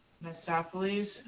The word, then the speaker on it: Mephistopheles: Mephistopheles, I think I said it right.